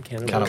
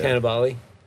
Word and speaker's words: Cannavale: Is that how you say his name? – Cannavale. Cannavale. – Yeah. Cannavale.